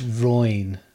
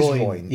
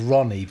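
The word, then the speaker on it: Roine: How do you pronounce his first name? – It's Royne. – It is Royne. – Yeah, because I thought it was Ronnie, but... – I didn't know if it was like Roynia.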